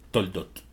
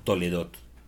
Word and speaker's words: Toldot: Muy bien, nuestra parashá el día de hoy tiene por nombre Toldot o Toledot, como algunos lo pronuncian.